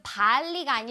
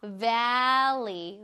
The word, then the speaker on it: valley: 그 발리가 아니라요. valley. valley.